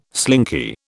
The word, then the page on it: slinky: British English